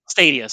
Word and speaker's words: Stadia: Wait, Stada or Stadia? – Stadia, sorry. – Okay. – I can never pronounce that right.